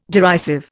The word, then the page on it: derisive: Indian